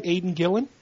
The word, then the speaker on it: Aidan Gillen: Aidan Gillen? – Yep.